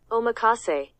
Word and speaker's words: omakase: omakase. – Omakase. – Omakase. – Omakase? – That's not even hard. – Omakase. – Omakase. – Omakase.